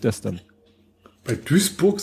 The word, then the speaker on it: Duisburg: Er schrieb, ich glaube, und jetzt spreche ich es mal falsch, also wie auch immer, Duisburg spricht sich nicht Duisburg, sondern Duisburg.